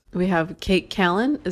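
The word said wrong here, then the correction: Kato Kaelin: Is that how you say your name? – It's Cato Kaelin.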